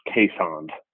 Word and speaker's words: Keeshond: There's a dog that's commonly mispronounced, the Keeshond. It's got two E's from Dutch, you know, like Holland. And it's actually pronounced Caisond.